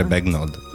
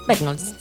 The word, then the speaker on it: Bagnold: Però non credo che loro abbiano la gnu. – Infatti per quello mi è venuto a dire Bagnold. – Bagnold, sì.